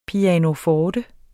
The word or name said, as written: PIANOFORTER